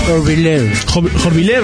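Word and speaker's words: Horvilleur: Además de Fernández, participan músicos como Dante Spinetta, Emmanuel Jorviler, no sé cómo se pronuncia, nunca supe. – Jorviler. – Ahí va. – Muy bien.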